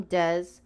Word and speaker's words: DE$: what you heard at the beginning of the podcast, that was Young Dez. You can find his music on Apple Music. It's Young and then it's spelled DE$.